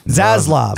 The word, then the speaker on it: Zaslav: And so the CEO of Discovery, David Zavlav, is that how you say it? – I think he made that up. – Let's call him Dave. – Zaslav. – Ask A.R. Clark. – Oh, he's Zaslav. I powered him off.